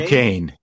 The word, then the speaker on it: Duquesne: Is it Duquesne? – Duquesne, yep.